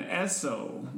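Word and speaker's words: Essop: That was my main man, Esso. That's E-S-S-O-P. The P is silent, like the D in Django. From ZFG, and the title of that track was Blowing It Up.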